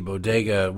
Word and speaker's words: bodega: It's not a complicated word. It is B-O-D-E-G-A. There's no other way to really, well, not normal people for another way to pronounce it. Bo-de-ga. There you go. Syllable, syllable, syllable.